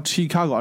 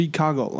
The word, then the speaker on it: Chicago: 那么如果说要一定要讲一讲有什么东西可讲呢，就是它的发音了哈，不叫。不叫 Chicago，而是 Chicago 啊，OK，就是它的发音了。